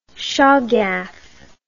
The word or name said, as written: chargaff